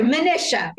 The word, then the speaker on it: Manisha: Not to worry. Manisha. – Manisha. Oh my God. Rhymes with Patricia. Oh gosh, my pronunciation is terrible.